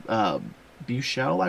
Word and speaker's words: Buechel: His name is Alex Buechel, I believe is how you say his last name. B-U-E-C-H-E-L. Mm-hmm. How'd you say that? – B-U-E-C-H-E-L. Buechel? – Yeah, Buechel.